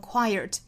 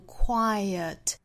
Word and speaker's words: quiet: 首先第一个词要注意的就是 quiet 这个词的发音，我们要注意里头呢是有一个小的 schwa sound，很多同学会把 schwa sound 混淆成 r，读成 quiet，一定注意是错误的，是读 quiet quiet quiet，这个时候舌头一定不能向里卷。